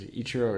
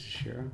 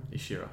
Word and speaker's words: Ishiro: I don't know if it's Ichiro or Ishiro. I think it's Ishiro. – Ishiro Honda, yeah.